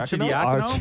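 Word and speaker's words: Achiuwa: Archie Diacono? – Archie Diacono? – Did I pronounce his name right? – Yeah.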